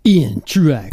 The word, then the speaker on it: Truax: So the bourbon we're having tonight This was one that I never had until we interviewed Ian Truax. – Can't say it like that. – Ian Truax. – There you go.